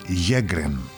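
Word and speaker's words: Sjögren: Πήρε το όνομά του, λέει, από τον Σουηδό οφθαλμίατρο Χένρικ Σέγκρεν. Στα σουηδικά προφέρεται Γέγκρεν.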